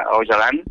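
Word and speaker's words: Öcalan: Pues es una pregunta que se podría extender también muchísimo, ya que se pronuncia Ocalan, su C se pronuncia en ese sonido.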